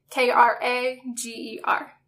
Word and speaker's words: Cameron Krager: It's CameronKrager.